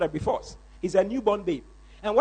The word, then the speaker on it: brephos: That word newborn babes, that word babes there is brephos. Brephos.